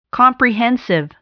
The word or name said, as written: comprehensive